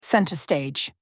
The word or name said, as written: center stage